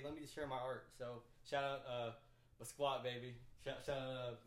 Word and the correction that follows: Basquiat: I'm asking, is it pronounced like that or is it Basquiat? – Basquiat. Yeah, so okay, I'm really awful with names.